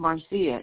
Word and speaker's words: Marcia: My first name is Marcia. It's spelled M-A-R-C-I-A.